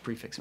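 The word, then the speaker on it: prix fixe: Is there a reason more restaurants don't do a prefix? I always find I like the pricing of it. Like, Thanks for pronouncing the X. People say prefi. I'm like, it's there's an X.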